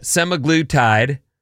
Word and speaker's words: semaglutide: Studies have found that semaglutide, is that how you say it? – Yeah.